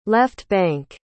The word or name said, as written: left-bank